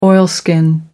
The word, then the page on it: oilskin: American English